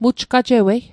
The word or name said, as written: mujgajewei